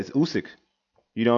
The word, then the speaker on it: Usyk: Versus, we discussed it with Alexander Usyk. – Mm-hmm. – I said that correctly, right? – Yeah, you said it. Usyk.